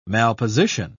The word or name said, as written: malposition